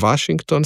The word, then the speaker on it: Washington: Washington z dvojnim V-jem, SH, NG, ne, čeprav je K izgovorjen, in imamo potem Washington, seveda pa sem izgovoril zobno-ustnični V pred samoglasnikom, ker je to sistemsko pravilo za slovenščino in seveda ta ton sem ohranil zaradi sklanjanja, torej Washington, washingtonski, ne pa Washington, washingtonski.